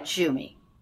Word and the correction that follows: Jumi: It's like you and me, Yumi. – Yumi. Okay. Yeah. I knew I was getting it wrong.